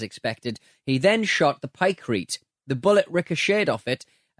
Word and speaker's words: pykrete: it's pronounced pycrete, like concrete, presumably.